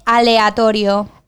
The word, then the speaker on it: aleatorio: El literario. No, rio is at the end, so it's a literario. – Vale, esa es la pronunciación inglesa de aleatorio. – Aleatorio. – Yeah, you got it.